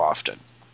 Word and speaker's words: often: I hate when they do this, and it occurs far too often. Ooh, I pronounced the T in often.